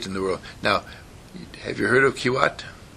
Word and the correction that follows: Kuwait: How do you pronounce it? It's over there. And the it's a little nation on the edge of Iraq, Iran. – Kuwait. – Say it. – Kuwait. – Yeah.